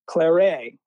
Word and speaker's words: Clairet: And for me, there's a rosé called Claret, which is spelled C-L-A-I-R-E-T, Claret, which is produced in the Bordeaux region of France.